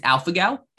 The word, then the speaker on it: alpha-gal: It's alpha-gal, A-L-P-H-A-G-A-L syndrome, alpha-gal syndrome.